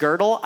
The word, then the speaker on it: Gödel: A guy named Kurt Gödel, I know his name looks like Godel, but it's pronounced Gödel. I don't know why.